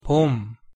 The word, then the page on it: 봄: Korean